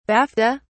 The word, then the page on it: bafta: British English